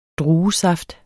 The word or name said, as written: druesaft